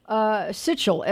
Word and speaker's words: Sichel: Well, no, it's like Sichel, S-I-C-H-E-L.